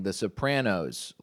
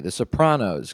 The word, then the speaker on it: Sopranos: That's how deep you can tell I'm into The Sopranos, is that I'm pronouncing it correctly and not saying The Sopranos like some other fucking mooks, eh? Some fucking fanooks who say some shit wrong. No, I'm saying it correctly. The Sopranos, because that's the way his last name is pronounced.